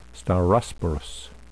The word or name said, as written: staurosporous